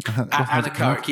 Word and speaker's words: anechoic: Anechoic? – Anechoic, yes.